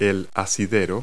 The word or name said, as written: handgrip